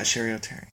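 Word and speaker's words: Cheri Oteri: And then other people just like that we kind of knew through going through Groundlings, like Jillian Bell came and, Sherry O'Terry. – Oh, yeah, yeah. – From SNL came. Am I saying her name right? – Yeah, Sherry O'Terry.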